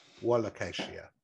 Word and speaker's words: Wallachia: You say wool. There's no O's in the beginning. – Whoa. W-O is how they say you pronounce it. Wallachia.